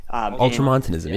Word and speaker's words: Ultramontanism: Ultramontanism, yeah.